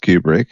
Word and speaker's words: Kubrick: Kubrick, pronounced Kubrick, born July Kubrick.